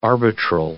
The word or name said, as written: arbitral